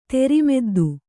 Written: ತೆರಿಮೆದ್ದು

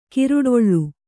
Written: ಕಾರೊಡಲು

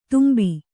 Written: ತುಂಬಿ

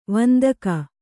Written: ವಂದಕ

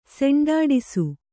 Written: ಸೆಂಡಾಡಿಸು

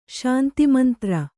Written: ಶಾಂತಿಮಂತ್ರ

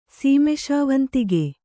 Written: ಸೀಮೆಶಾವಂತಿಗೆ

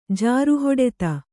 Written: ಜಾರುಹೊಡೆತ

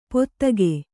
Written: ಪೋದಗೆ

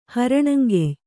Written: ಹರಣಂಗೆಯ್

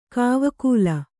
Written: ಕಾವಕೂಲ

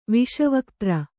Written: ವಿಶ್ವಕದ್ರು

